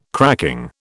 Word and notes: cracking: British English

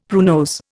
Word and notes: pruinose: Indian

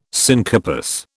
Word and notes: syncarpous: British English

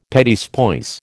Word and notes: petits pois: American English

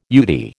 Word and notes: UDI: American English

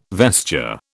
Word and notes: vesture: British English

